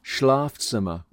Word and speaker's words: Schlafzimmer: Schlafzimmer with a long A.